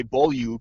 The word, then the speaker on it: Beaulieu: The expert apparently is Rick Ralph, who actually knows, I'm going to say Beaulieu, because that's what Rick Ralph says. He knows him back from his junior days. Apparently, he asks for it to be pronounced Beaulieu.